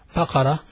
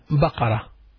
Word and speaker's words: بقرة: When you studied the animals, you studied Baqarah, which means cow. Baqarah means a cow. Ba, qaf, ra, and round ta. Baqarah means a cow.